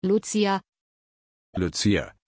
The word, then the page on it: lucia: German